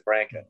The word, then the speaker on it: Branca: Glenn's, he pronounced his name Branca. I used to say Branca, but he always said Branca. I'm too, you know, so I say Glenn Branca.